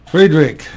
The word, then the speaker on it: Friedrich: Friedrich is the way the Germans pronounce it.